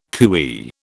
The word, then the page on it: cooee: British English